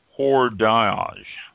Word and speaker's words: hors d'âge: H-O-R-S-D apostrophe A-G-E. And there's the age with that little mark above it. So hors d'âge, I believe it's pronounced.